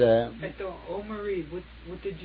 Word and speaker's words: Aomori: I went to Aomori. Aomori was headquarters, the Japanese Army headquarters. Aomori. – Aomori. How do you spell it? A-M-U-R-I? – O-M-O-R-Y.